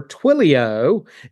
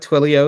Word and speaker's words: Twilio: Is currently Global Event Marketing Specialist for Twilio, and we'll get to hear what Twilio is all about.